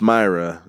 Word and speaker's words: Moira: It's Moira, but I feel like they pronounced it because she's Scottish. It was more like a Moira.